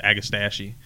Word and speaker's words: agastache: So the next one on the list is agastache, or even some people pronounce it agastache.